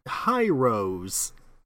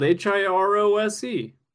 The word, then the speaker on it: Hirose: But come on, high rose. Yeah. It's how it's spelled H I R O S E. All right. – what? Just, just how? Okay, then how is it supposed to be pronounced? – Hirose.